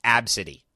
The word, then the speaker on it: ABCDE: So yes, there is a child who has been subjected to mass abuse by her parent, and named ABCDE, pronounced Ab City.